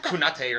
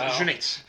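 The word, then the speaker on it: Cüneyt: And it was spelled like C-U-N-A something. It was weird. It looked like Kunate or something, but it's Junit.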